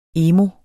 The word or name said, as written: emo